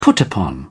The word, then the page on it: put-upon: British English